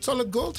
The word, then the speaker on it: Solid Gold: En ik denk nu ook, als mensen dit horen, denken ze van wacht eens even, de Solid Gold Brothers. Zeg ik het goed? Solid gold? Solid gold gewoon.